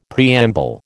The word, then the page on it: preamble: American English